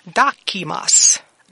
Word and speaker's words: dokimos: And the word that I have today is called dokimas. Dokimas. And that word is spelled D-O-K-I-M-O-S. Again, D-O-K-I-M-O-S.